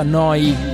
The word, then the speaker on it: Neu: Si scrive Neu, si pronuncia Noi, vuol dire nuova, nuova emittente urbana, live da Bologna 7 giorni su 7.